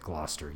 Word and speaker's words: Gloucester: So, a good example that people would recognize instantly is like Gloucester. You would, if you looked at Gloucester as it's written on the page, it doesn't resemble how it sounds.